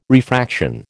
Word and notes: refraction: American English

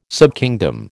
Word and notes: subkingdom: American English

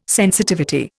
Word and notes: sensitivity: Indian

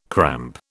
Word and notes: cramp: British English

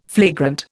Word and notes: flagrant: Indian

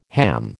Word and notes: ham: American English

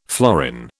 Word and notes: florin: British English

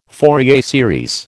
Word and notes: Fourier series: American English

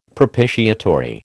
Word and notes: propitiatory: American English

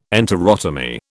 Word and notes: enterotomy: British English

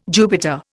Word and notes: Jupiter: Indian

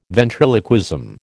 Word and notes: ventriloquism: American English

